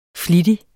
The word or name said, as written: FLITTIGERE